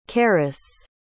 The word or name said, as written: keres